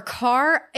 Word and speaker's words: Kerr: That's with Deborah Kerr. Or Carr. I always forget which one it actually is. It looks like Kerr, but I think it's pronounced Carr.